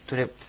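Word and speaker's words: トゥレプ: そのトゥっていうのがですね、まあ、外来語などで、あの、英語のトゥモローとかトゥデイとか言いますけどね、それと同じで、トゥという発音です。とうでもないし、つでもないです。トゥという、カタカナで書くときは、とに丸をつけて書くことが多いんですね。もしくはとにちっちゃいをつけて書きます。日本語にはない発音なんで、ちょっと、難しいかもしれません。トゥレップですね。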